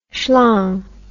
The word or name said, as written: shlong